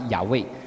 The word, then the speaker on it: YHWH: 总括来说，神的名在希伯来文原文的它的书写是 YHWH，发音大概是亚卫，然后但是犹太人就把它读成 Adonai，就是主的意思。